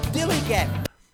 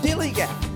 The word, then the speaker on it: DILLIGAF: That's spelled D-I-L-L-I-G-A-F, dilly-gap. And it means... Do I look like I give a fuck, dilly gap? – Thanks, Kevin Bloody Wilson.